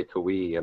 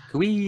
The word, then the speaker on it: Kawhi: I'm still struggling with Kawhi Leonard, though. I always go to say Kawhi, and I don't know why. I think it's just the way it's spelled. – Kawhi.